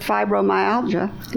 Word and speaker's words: fibromyalgia: But I wonder how many people with fibromyalgia, am I saying that right? – Yep.